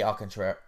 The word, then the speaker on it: Alcantara: I was thinking Strider, but I'm leaning more towards Sandy Alcantara? How do you say it? – Alcantara.